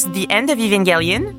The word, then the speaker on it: The End of Evangelion: Ça se prononce The End of Evangelion.